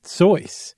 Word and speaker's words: Seuss: So, we are talking, we keep saying Dr. Seuss. Everybody knows him as Dr. Seuss, but apparently the correct pronunciation is Seuss.